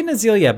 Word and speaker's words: Nazila: They say Nazilia, but it's spelled Nazila.